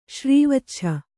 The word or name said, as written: ಶ್ರೀವಚ್ಛ